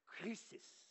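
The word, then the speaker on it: krisis (κρίσις): we would pronounce it krisis, K R I S I S, which, if you look at it on paper, looks a lot like the English word crisis, except in Greek it's spelled with a K, in English it's spelled with a C.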